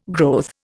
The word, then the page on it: growth: Indian